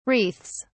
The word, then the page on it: wreaths: British English